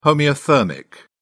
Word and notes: homeothermic: British English

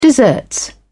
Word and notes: deserts: British English

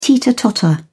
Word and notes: teeter-totter: British English

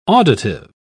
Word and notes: auditive: British English

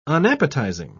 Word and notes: unappetising: British English

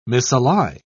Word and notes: misally: British English

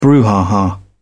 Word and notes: brouhaha: British English

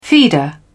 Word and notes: feeder: British English

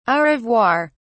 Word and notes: au revoir: British English

